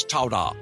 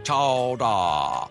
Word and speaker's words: chowder: A clam's all like, oh, I'm so happy I didn't get turned into New England clam chowder today, pronounced regionally as chowda, chowda.